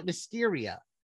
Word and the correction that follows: Mystara: But the setting is called Mystaras. I guess that's Mystara, not Mysteria. I guess I've been pronouncing it wrong.